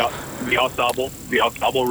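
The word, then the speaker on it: Au Sable: The, how do you say it? Aus stable, boat, river boat. – Yep. The Aus Sable river boat.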